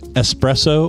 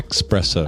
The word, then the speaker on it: espresso: Espresso or expresso? – Oh, yeah. – Mm-hmm. – Mm-hmm. – People say espresso.